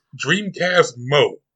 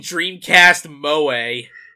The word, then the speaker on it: Dreamcast Mode: This is the first one, Come Back Around, featuring the vocals from Dreamcast Mode. – Oh, is that how it's pronounced? I was saying Dreamcast Moe.